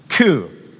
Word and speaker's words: Koo: His name is Justin, rhymes with Sue, Koo.